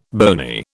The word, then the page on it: bony: British English